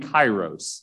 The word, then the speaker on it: kairos: Chronos is spelled C-H-R-O-N-O-S. C-H-R-O-N-O-S. Chronos, kind of like chronological. And the second word is kairos. It's spelled with a K at the beginning. K-A-I-R-O-S. K-A-I-R-O-S.